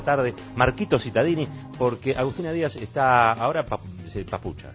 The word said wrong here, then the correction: pachucha: digamos, ¿cómo se dice? – Pachucha. – Eso, pachucha.